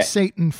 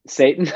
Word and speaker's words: seitan: The woman great, at the Flore Cafe called it Seitan. – Satan pronounced like the Prince of Darkness, but you oh say Satan and I like Satan yeah.